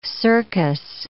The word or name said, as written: cercus